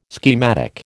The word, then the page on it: schematic: American English